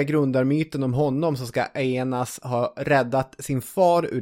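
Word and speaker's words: Aeneas: Men den mycket kändare grundarmyten handlar ju om Aenas. Ska vi uttala det så? – Ja, jag kommer inte på något bättre sätt. Aenas.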